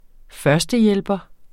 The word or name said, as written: førstehjælper